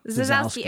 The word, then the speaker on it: Zasowski: Zasowski. – Zasowski. It rhymes with Wazowski.